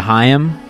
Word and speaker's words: Haim: I mean, the last 2 weeks I've gotten really into Haim, which is... – Thank you to Courtney and Glenda for correcting our mispronunciation.